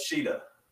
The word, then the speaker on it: Shida: Am I saying her name right? I don't care. – Yeah, Hikaru Shida.